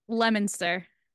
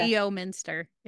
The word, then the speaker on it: Leominster: Leominster. – Leominster. – You don't pronounce Leominster. It Leo Minster. It's Leominster.